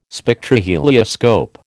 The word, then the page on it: Spectrohelioscope: American English